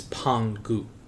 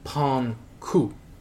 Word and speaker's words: Pangu: And that involves the god known as Pangu, or Pangku, depending on the pronunciation.